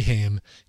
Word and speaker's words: Haim: I love the band Haim, H-A-I-M. No, you don't pronounce it like Corey Haim, even though it's spelled the same way. They're pronounced Haim.